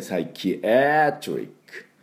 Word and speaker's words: psychiatric: で、で、 CHIA で、サイキアトリック。